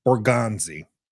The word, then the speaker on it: Borgonzi: Uh, Bears assistant GM Ian Cunningham, 49ers assistant GM Adam Peters, Eagles assistant GM Alec Halaby, and Chiefs assistant GM Mike Borgonzi, who I was able to pronounce correctly today. On Monday, I totally butchered it.